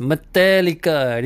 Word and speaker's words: Metallica: Pronunciation dia kena betul eh. Metallica, bukan Metallica, bukan eh. Mak-mak Melayu suka cakap Metallica, Metallica. Salah. Dia punya pronunciation is Metallica. Dia nak kena ikut American slang sikit. Cakap lagi sekali sama dengan saya, Metallica. Tu cara sebutan yang betul.